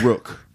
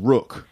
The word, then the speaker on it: Rook: Also I've been called Rook. – Rook? – Rook. – Oh. – R-double-O-K, but the pronunciation Rook.